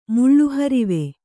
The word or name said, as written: ಮುಳ್ಳುಹರಿವೆ